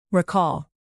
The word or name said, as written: recall